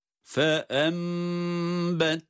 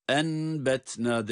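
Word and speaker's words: Fe embetna (فأنبتنا): Yani nun mime dönüşüyor. Dikkat buyurun. Fe embetna, enbetna değil fe embetna diye iklab ile okunuşu bu şekilde görmüş ve tatbik etmiş oluyoruz.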